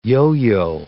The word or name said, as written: yo-yo